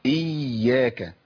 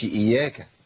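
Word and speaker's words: إيّاك: ما هو بدل ما يكتبوا ياء ساكنه ويحطوا عليها السكون وبعد كده ياء متحركه، فكتبوا حرف واحد وحطوا الشده دي عشان تعرف ان في هنا حرفين، يبقى لازم انطق ايه؟ حرفين. يبقى ايه؟ اياك، ما اقولش اياك.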